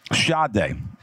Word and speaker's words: Sade: Sade. And for anyone else, that it's spelled S-A-D-E, Sade.